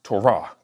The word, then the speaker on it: Torah: Actually, Torah, because the accent comes on the tonic syllable at the end, right?